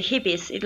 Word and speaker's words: Hippies: No, you said it correctly. Hippies, like in English.